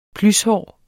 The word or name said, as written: plyshår